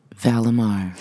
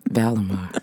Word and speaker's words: Vallimar: Val Lamar rhymes with buy a car. V-A-L-L-I-M-A-R. Val Lamar.